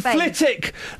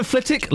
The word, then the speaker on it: Flitwick: The W in Flitwick. Local but not vocal, says Linda on Twitter. That's genius, isn't it? – Of course it is. – Thank you, Linda. The W in Flitwick, local but not vocal.